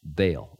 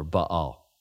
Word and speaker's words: Baal: It was the Phoenician version of the old Canaanite deity, Baal or Baal. You can pronounce it either way.